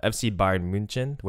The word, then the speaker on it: FC Bayern München: Which, fun fact, FC Bayern München, which is how it's said in German or Bavarian, translates to Bavarian Munich or Munich Bavarians.